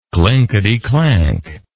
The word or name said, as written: clinkety clank